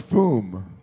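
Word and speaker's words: Füm: If there's an umlaut, it would be foom.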